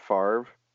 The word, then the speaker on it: Favre: Can we discuss that we pronounce it Favre, but it's definitely not spelled Favre? – No, it's Favre.